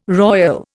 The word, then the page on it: royal: Indian